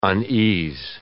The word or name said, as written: unease